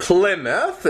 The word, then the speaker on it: Plymouth: And Plymouth is the pronunciation.